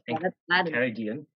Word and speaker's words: Enchiridion: Enchiridion. – Yes, Enchiridion.